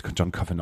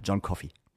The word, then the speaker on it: John Coffey: John Coffey im Übrigen. – John Coffey. Man hat mir gestern gesagt, das wird John Coffin ausgesprochen. – Auf keinen Fall, John Coffey.